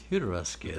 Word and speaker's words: Jodorowsky: Jodorowsky? – Jodorowsky, I think you pronounced it.